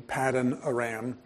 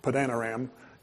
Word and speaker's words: Paddan Aram: So this morning, I want to look at the latter portion of the 20 years that he spent in Paddan Aram, or as some people pronounce it, Paddan Aram.